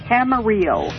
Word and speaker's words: Camarillo: Camarillo. – What's the name? – Camarillo. – San Marino. – No, it's Camarillo. Spelled okay. Like Amarillo with okay. A C in front of it. – Camarillo. Camarillo. – Yeah, but it's pronounced Camarillo. – Camarillo. – Camarillo. C-A-M-A-R-I-L-L-O.